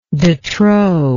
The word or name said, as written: detrop